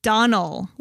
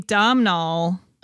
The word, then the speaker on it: Domhnall: It's Donald, like Donald with no D. – Oh, whoops. I've been saying it wrong for a very long time. – I've been saying Domhnall.